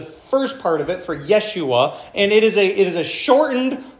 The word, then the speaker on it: Yeshua: Now, if you were Hebrew, you would pronounce it Yeshua.